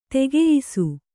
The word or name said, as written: ತಕ್ಕೆಯ್ಸು